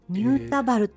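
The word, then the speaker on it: 新田原: ニュータバルって読むんですね、この、はい、新しい田んぼの原っぱと書いてニュータバルと、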